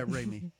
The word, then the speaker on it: Raimi: So it's the Raimi brothers that, Raimi, am I saying it right? – Yeah, Raimi.